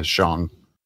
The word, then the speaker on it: Shang: Is it Shang? – That's the pronunciation. Okay. Yeah, Shang. We've been saying it. It rhymes with wrong, which is how we've been saying it for years. Mm. We've been saying it wrong.